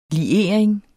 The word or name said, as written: LIERING